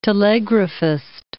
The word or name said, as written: telegraphist